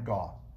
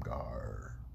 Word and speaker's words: Gaw: Camp Gaw. I got it. I said it right. Camp Gaw. Mm-hmm. Camp Gaw, Camp Gaw, Camp Gar. I don't like that one.